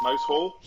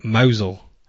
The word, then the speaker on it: Mousehole: But we're not, it's not the, how are they properly pronounced? – Oh, like Mousel. – Mousel, that's it.